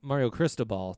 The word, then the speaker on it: Mario Cristobal: Mel Tucker and Mario Cristobal, their head coach.